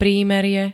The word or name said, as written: prímerie